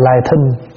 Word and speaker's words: lai thính: Thỉnh thoảng mày nghe ai đọc là lai thính, đọc cho đúng là lai thính.